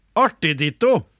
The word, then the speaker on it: artig ditto: Det, det jeg for så vidt har ramlet ned på, er artig-ditto. Uttales Artig, artig, artig ditto. Ja, artig ditto. – Uttales med en liten pause mellom ordene om en rytme som når man hilser på hverandre.